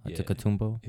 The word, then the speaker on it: Antetokounmpo: Giannis Antetokounmpo. – Yeah. – Antetokounmpo.